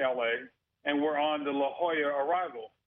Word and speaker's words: La Jolla: We were going into L.A., Los Angeles, and there's an arrival called the La Jolla arrival. But when you look at it, it's spelled with a J. It's spelled J-O-L-L-A.